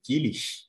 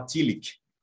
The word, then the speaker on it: Tillich: Tem duas formas que o pessoal costuma pronunciar o nome dele. Uma forma tentando se aproximar daquela pronúncia mais alemã, né? Então fica aquela coisa meio Tillich, aquele S meio esse sonzinho de mais afrescalhado ao estilo alemão. E o pessoal que pensa de uma maneira mais inglesada, né? Então fala Tillich. E aí facilita, como se fosse um Kali no final.